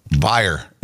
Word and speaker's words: Bayer: Bayer, the aspirin. – Bayer. – Bayer is how you really say that. – Bayer. – Yep, German brand, Bayer.